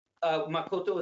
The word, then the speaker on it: Makoto: Makoto is how it's pronounced in Japanese. Makoto.